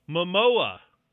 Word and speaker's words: Momoa: Momoa.